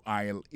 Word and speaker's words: aisle: Aisle là, c'est A-I-S-L-E. Vous prononcez aisle.